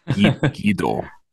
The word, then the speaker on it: Guido: It's pronounced in the German manner, Guido.